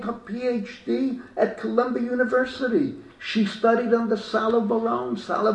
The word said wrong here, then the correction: Salo Baron: Baron was maybe one of the leading names in Jewish scholarship in the Salo Baron, I think you pronounce it. Salo Baron.